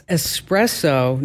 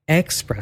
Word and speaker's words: espresso: It's espresso, not expresso.